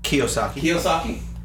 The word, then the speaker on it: Kiyosaki: Kiyosaki. Did I say that right? – Kiyosaki.